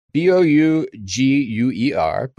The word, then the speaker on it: Bouguer: The Bouguer, B O U G U E R, but pronounced Bouguer gravity anomaly, is named after French scientist Pierre Smith, Pierre Bouguer.